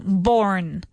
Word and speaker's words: Bourne: So it's pronounced just the same, Bourne.